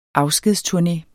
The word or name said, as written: afskedsturné